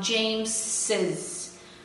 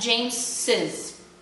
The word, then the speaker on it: James's: First of all, I want you guys to pay attention to the pronunciation of James's. The fact that I am talking about his reaction makes me pronounce the S like a double S.